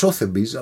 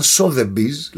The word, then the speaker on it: Sotheby's: Εκεί λοιπόν, εκείνη την περίοδο και ακόμα, η διασημότερη οίκη δημοπρασιών είναι το Sotheby's, που νόμιζα ότι προφέρεται Sotheby's, αλλά από ό,τι μου είπαν Sotheby's λέγεται, και το Christie's.